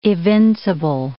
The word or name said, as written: evincible